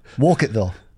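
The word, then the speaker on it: Wolcottville: Walcottville. – Walcottville. – So there is an L in there. It's W-O-L-C-O-T-T-V-I-L-L-E. – But are you pronouncing the T's? – Walcottville.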